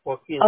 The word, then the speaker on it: Joaquín: Sí, Joaquín.